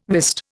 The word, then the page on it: wist: Indian